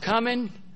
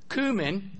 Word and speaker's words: cumin: It can be pronounced cumin, cumin, or cumin. All three are correct pronunciations.